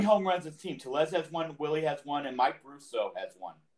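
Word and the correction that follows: Brosseau: Brasso, like, give him some respect. Homer off of Rollins Chapman. Whoops. – I apologize. How's it pronounced? – It's Brasso.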